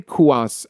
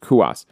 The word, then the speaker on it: Cuas: And when Jose Cuas, I believe that's how it's pronounced, Cuas, when he made his debut, this was a big story for the Royals.